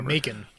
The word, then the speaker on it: Macon: Uh, Mackin is that how you say it? Mackin Blair, whatever. – Macon. Macon, Macon, Georgia. Macon bacon.